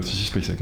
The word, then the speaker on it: Sissy Spacek: avec Sissy Spacek, mais j'écorche son nom. Sissy Spacek, non, c'est ça.